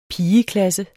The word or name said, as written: pigeklasse